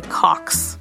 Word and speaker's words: Cocks: Or, you know, you can always consult one of Lisa's favorite turn-of-the-century texts, which is actually written by a man whose name is actually Cox in 1907. – I should say also, it's not spelled C-O-X, it's spelled C-O-C-K-S.